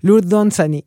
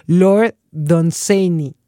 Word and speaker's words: Dunsany: Me gusta, pero bueno, más bien siempre lo he mexicanizado demasiado como Lord Dunsany. En realidad, si lo quisiéramos pronunciar bien, sería Lord Dunsany.